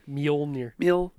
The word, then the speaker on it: Mjolnir: I really can't pronounce Mjolnir. – Mjolnir.